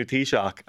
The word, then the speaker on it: Taoiseach: And he was like, here you guys are getting a new Taoiseach.